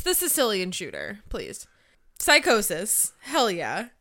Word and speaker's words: Psicosis: It's Little Guido versus a returning Psicosis. Or... – No. Psicosis. – Yes, Joey Styles has a line of, like, that's the Spanish pronunciation and spelling, more or less going, it's legally distinct.